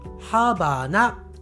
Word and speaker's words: ハバナ: 英語ですと、ラハバナ、ね、ラ、 LA というのが一番初めについていますが日本語ではこの「ら」というのを発音しません日本人は「ハバナ」と言いますキューバの首都キューバのキャピタルはハバナと発音します